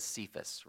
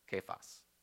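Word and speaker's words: Kephas (Κηφᾶς): Modern English speakers, we read this as Cephas, right? Because that's how modern English works. Older English pronounced it correctly, Kephas.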